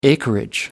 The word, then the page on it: acreage: American English